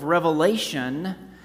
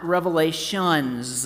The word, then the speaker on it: Revelation: So today we are beginning our much anticipated series on the book of Revelation. Now, the first thing we need to be clear on together is the title of the book we're studying. It is the book of Revelation, not Revelations.